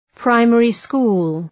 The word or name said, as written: primary school